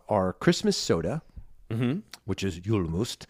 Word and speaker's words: Julmust: This is how you say it in Sweden. Here we go. – Julmust. – Julmust. – So it's spelled J-U-L-M-U-S-T and Jul is for Yule.